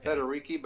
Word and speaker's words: Federighi: Federighi, but he's the guru at Apple in terms of software.